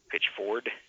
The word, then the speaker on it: Pitchford: You can also find me on Facebook if you search for my name, D-O-N-N-I-E. And the last name is spelled just like pitch, like pitching a baseball, and it's spelled like one of Lum and Abner's sponsors, the Ford, Pickford.